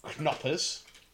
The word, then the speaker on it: Knoppers: So it's spelt with a silent K. – Okay. – I'm assuming it's German. Knoppers.